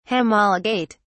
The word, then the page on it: homologate: British English